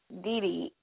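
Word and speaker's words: DiDi: And the young lady, just so you know, is Dee Dee. Well, let me not say it wrong. Let me spell it for you. It's D-I-D-I.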